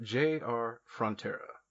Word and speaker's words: J.R. Frontera: I'm talking about J.R. Frontera.